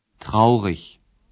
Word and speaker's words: traurig: بعضی از صفات هم به پسوند گ ختم میشن ولی متوجه باشین که در زبان آلمانی ایگ که در آخر صفت میاد ایش تلفظ میشه نه ایگ traurig Das macht mich traurig.